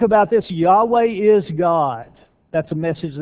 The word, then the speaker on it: Yahweh: The first part, the J and the O, actually the name of God, we think, or scholars think, that it may have been pronounced as Yahweh.